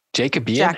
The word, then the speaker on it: Jacobean: It ends with when they were together, the Jacobean and the Victorian ages were juxtaposed, which now reminds me, how do you say Jacob? Is it Jacobean? – Jacobean.